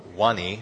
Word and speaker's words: Vanni: And so he would go through and he'd count the pills or he'd pull them and he'd give them to the pharmacist, which is Wani. Her name is spelled V-A-N-N-I, but she is a small Cambodian woman.